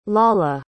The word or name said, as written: lolla